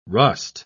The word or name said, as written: rust